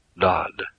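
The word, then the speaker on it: Dad (דד): To pronounce it, you have to put in a vowel, so you have Dad, D-A-D.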